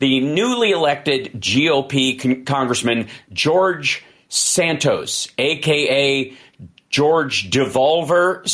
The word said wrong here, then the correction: Devolder: Santos. I think that's how it's pronounced. Devolder, George Devolder Santos, which...